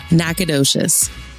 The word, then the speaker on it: Nacogdoches: Nacogdoches.